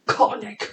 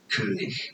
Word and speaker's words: König: Name pronunciation in German. It is pronounced as König. – König. – König. König. In English. It's normally pronounced as Koenig.